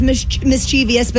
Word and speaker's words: mischievous: It is oftenly mispronounced as mischievous, but it's actually mischievous.